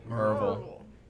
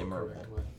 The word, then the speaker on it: Maryville: Well, Thursday, which was our first full day, I went back home to good old Maryville, Tennessee. – Maryville. – If you would like to pronounce it that way, you can. – The correct way. – It is the correct way to say Maryville.